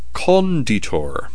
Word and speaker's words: conditor: Now, there are two words in Latin, two verbs, which can give us a word spelled C-O-N-D-I-T-O-R. And that's, of course, the first word of the hymn. The verbs are condo, and the infinitive is condere. That gives us the result of conditor, with the emphasis on the first syllable, while condio, condire, also produces a word spelled exactly the same, but the emphasis is on the second syllable, conditor. So there's a difference between conditor and conditor. They're spelled the same, but they're pronounced differently.